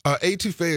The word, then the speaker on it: étouffée: Never tried I can never pronounce the word étouffée.